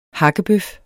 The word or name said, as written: hakkedreng